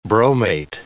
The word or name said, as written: bromate